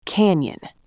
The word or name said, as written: canyon